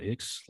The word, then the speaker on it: IX: It's actually pronounced though. I think it's pronounced Ix, like the word. – Oh, really? – Ix. Yeah, yeah, it's not a number.